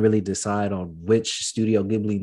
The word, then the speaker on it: Ghibli: Coming in for number one is, I might even butcher it, Studio Ghibli. – Ghibli.